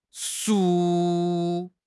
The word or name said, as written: 竖